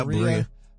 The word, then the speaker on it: Berea: They're doing a little mic check up there in Berea. That's how you pronounce it, right? – Yeah, Berea.